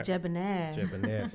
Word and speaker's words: Jebonair: Jebonair.